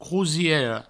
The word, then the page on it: crosiera: crousiero